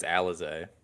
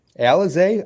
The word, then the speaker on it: Alize: Is it Elise? Elise? Oh, Elise Johnson? – I think it's Alize. – Alize, I wasn't close at all.